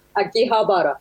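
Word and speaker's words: Akihabara: Aquí Habara, sí.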